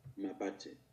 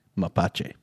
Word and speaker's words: mapache: Trying to find the thing where it'll say how to pronounce it. Ooh, here we go. – Never mind, I'm wrong. – Mapache. – Mapache.